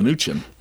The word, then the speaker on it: Mnuchin: I learned how to pronounce it this way. – Mnuchin.